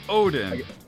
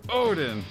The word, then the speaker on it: Odin: Only if you, Odin, Odin. Accent the wrong syllable like that guy did.